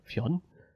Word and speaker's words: Fionn: Fion? F-I-O-N-N? Fionn Whitehead. Whitehead, auch ein interessanter Name. Der, der Herr Whitehead. Fionn. Fionn? Der Fionn, ja. Fionn, Fionn Whitehead, würde ich so Whitehead. aussprechen, ja.